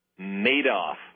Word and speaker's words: Madoff: Madoff.